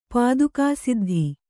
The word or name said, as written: ಪಾದುಕಾಸಿದ್ಧಿ